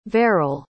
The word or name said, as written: verrill